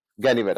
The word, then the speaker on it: Ganivet: How do you pronounce that name? Ganivet. I just want to make sure. Okay, great.